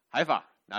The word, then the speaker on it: 海法: 另外一个城市叫加法，加法，我说对了，感谢主。海法，No, I said it wrong, never mind. 海法，我说错了。